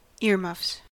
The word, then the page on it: earmuffs: American English